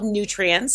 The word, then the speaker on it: Nutrience: It's called Nutrience, spelled Nutrience, because it's phonetically nutrients.